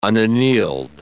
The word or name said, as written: unaneled